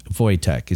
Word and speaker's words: Wojtek: So if I just looked it up, it's Wojtek is the bear. – Wojtek. Yeah. – Wojtek is how it's pronounced.